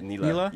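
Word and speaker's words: Nilah: I mean, you guys probably saw that Neela, was that how we're pronouncing it? – Neela? Yep.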